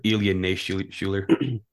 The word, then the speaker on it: Ilya Naishuller: So Ilya Naishuler is how you say his name, right? – Ilya Naishuler. – born in Moscow.